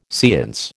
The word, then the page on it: seance: American English